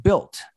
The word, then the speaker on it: Bilt: He didn't say DBEI, he said Built. And we're going to learn over time about what Built mean. And it's spelled B-I-L-T.